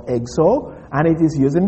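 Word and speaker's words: Exo (ἔξω): Now, the word outsiders, interestingly, comes from a simple Greek word, exo. Exo. Now, sometimes I write these things, but it is E-X-O. That O is a long O in the Greek.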